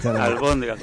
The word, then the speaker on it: albóndigas: Almóndigas. – Albóndigas, con velas. – Ah, yo digo almóndigas.